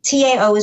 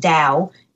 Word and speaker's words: Tao: So TAO is pronounced Tao, not Tao, as it may appear.